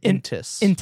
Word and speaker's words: Intis: How does an intist? – How are they born? – No T at the end. Intist. – Intist. – No. No T. – Intis. Thank you.